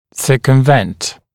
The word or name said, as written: circumvent